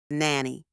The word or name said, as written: nanny